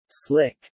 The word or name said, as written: slick